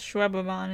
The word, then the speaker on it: Schwebebahn: Oh, like Autobahn. Like, oh, like the, you know, Autobahn. – Schwebebahn. Schwebebahn. – Yeah. – So it wasn't as bad as I thought it would be. It actually is kind of pronounced how it looks.